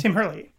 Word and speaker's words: Herlihy: It is written by, in quotes, I'm saying, Adam Sandler, Fred Wolf, and Tim Herlihy? – Tim Herlihy.